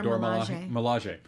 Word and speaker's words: Dora Milaje: They showed a lot, apparently, of the Dora Milaje. I'm saying that correctly. Dora Milaje.